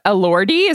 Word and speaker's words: Elordi: Jacob Elordi, is that how you pronounce it, Liz Kelly? – Yes.